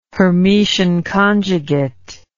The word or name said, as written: Hermitian conjugate